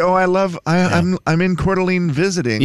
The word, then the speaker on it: Coeur d'Alene: Is it pronounced Coeur d'Alene or Coeur d'Alene? – Coeur d'Alene.